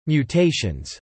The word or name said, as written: mutations